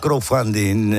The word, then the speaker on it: crowdfunding: Lo que ahora se llama crowdfunding, Alberto, no sé si lo pronuncio bien. – Sí, perfectamente.